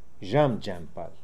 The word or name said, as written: Djamdjampal